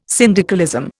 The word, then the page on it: syndicalism: Indian